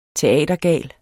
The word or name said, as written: teatergal